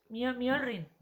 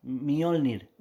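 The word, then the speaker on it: Mjölnir: Mjolnir. – Mjolnir. – Mjolnir.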